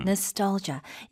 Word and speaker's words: nostalgia: Nostalgia. 이거는 눈으로 보시는 게 좋아요. – 네. – 스펠링은 N-O-S-T-A-L-G-I-A. 발음은 다시 한번 Nostalgia. – 아, 첫 번째 A의 액센트가 좀 가면서 Nostalgia 이런 식으로 하게 되면 뭔가 과거를 그리워하는 그런 네.